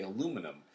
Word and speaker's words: aluminum: And that's why the Brits say aluminium and we say aluminum.